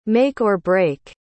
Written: make-or-break

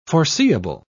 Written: forseeable

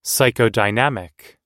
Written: psychodynamic

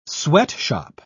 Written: sweatshop

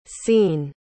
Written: cein

